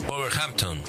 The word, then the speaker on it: Wolverhampton: Wolverhampton. – Sí. – ¿Cómo se dice, vos que sabés? – Wolverhampton.